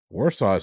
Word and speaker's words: Warsaw: Warsaw is correct. How the hell did you get that one? – I was W pronounced like V.